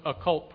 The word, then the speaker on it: occult: Now, what is happening in our church today is especially syncretism with occult principles. Now, again, when I say occult, I always spell it because some people think I say cult. I'm not talking about cult, C-U-L-T. I'm talking about occult, O-C-C-U-L-T.